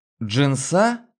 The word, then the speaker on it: джинса: «Джинса?» С ударением на последний слог.